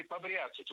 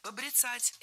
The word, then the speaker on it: побряцать: Побряцать неверно. Правильно, побрицать. Это слово имеет настолько непривычное ударение, что большинство людей не слышали и не представляют, что правильно побрицать, брицать. Ударение в этом глаголе и в однокоренных глаголах ставится за корнем.